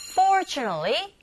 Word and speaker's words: fortunately: fortunately로 발음하지 않고, 그렇게 들리지 않고요. fortunately, t 사운드 자체가 아예 안 들리죠. 그래서 fortunately, nally, l, l, 이렇게 되겠죠.